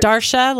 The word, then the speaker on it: Darsha: Darsha, like Marsha with a D.